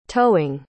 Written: towing

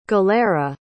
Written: gallera

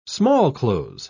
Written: smallclothes

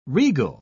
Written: regal

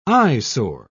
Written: eyesore

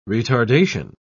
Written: retardation

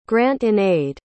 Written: grant-in-aid

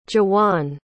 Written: juwan

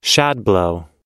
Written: shadblow

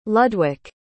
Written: ludwik

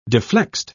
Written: deflexed

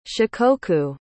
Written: shikoku

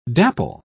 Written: dapple

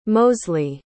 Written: mosley

